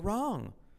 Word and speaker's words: wrong: Y la W no se pronuncia. Wrong. Porque está ahí. Nadie lo sabe.